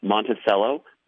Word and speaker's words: Monticello: Following towns are pronounced Monticello, Bement, Hoopston, which is in the 217 area code, just like Springfield, but it's actually 5 miles from Indiana.